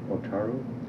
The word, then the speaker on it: Otaru: O-T-A-R-U. – Otaru? – Otaru, yeah.